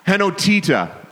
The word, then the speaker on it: henotita: Henotita is how it's pronounced in Greek, and it really in Greek, and it really just means oneness.